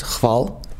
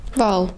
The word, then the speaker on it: Hval: It's just Hval. – Val. – Val. Okay. – Don't worry about the, the H.